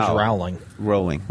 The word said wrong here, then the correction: Rowling: Rolling. You have to know that. You have to know how to pronounce her name.